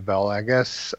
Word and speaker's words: Valagas: That goes by Valagas on Twitter, V-A-L-A-G-A-S on Twitter. So, V-A-L-A-G-A-S.